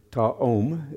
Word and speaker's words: ta'om: The Hebrew word ta'om is how it's pronounced.